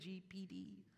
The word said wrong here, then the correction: GPT: Something like that. GPT, yes.